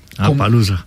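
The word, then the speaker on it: Appaloosa: Es, nos pregunta un oyente, Juan Garzón, ¿qué tal es el Apalusa? ¿Sí lo pronuncié bien? – Sí, Apalusa.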